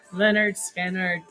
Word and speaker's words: Lynyrd Skynyrd: Tuesday Gone, Lynyrd Skynyrd, pronounced Lynyrd Skynyrd, 1973.